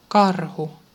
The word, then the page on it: bull: /bʊl/